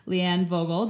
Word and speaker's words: Leanne Vogel: And so without further ado, I am going to introduce to you Leanne Vogel.